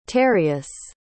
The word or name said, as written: tereus